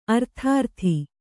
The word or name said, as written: ಅರ್ಥಾರ್ಥಿ